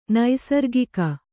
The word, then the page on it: ನೈಸರ್ಗಿಕ: Kannada